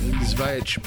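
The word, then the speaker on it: Żywiec: So there's a little bit of a maker's mark and Zvaich porter.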